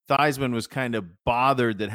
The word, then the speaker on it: Theismann: Theismann rhymes with Heisman. He changed the pronunciation of his last name.